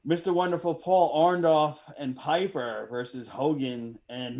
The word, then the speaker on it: Orndorff: We got WrestleMania 1, Hulk Hogan and Mr. T versus Roddy Piper and Orndorff.